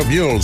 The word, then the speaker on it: Mule Skinner Blues: Io pensavo mule come le mule triestine. Invece no, mule Skinner Blues. Così la pronunciava Lorenzo Pilat, che saluto perché lui ci ascolta da Trieste, è vero?